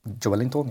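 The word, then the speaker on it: Joelinton: Jolinton, ja.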